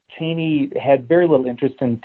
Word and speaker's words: Cheney: And Strook thought he saw something of interest in young Cheney, or Cheney, actually, as they pronounced it back then. It was Cheney in later years, but Cheney at the time.